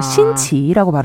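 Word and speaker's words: 신치 (辛奇): 이 신치라는 것은 매울 신자의 기이할 기자를 섞은 건데요. 아. 신치라고 발음합니다. 네.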